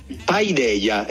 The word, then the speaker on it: Paideia: Filosofo, come si pronuncia Paideia? – Paideia, esattamente come l'hai pronunciato tu, maestro. Perfettamente.